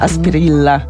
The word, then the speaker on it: Asprilla: Asprilla się pisze, Asprilla tam się wypowiada, bo to jest ten akcent pacyficzny.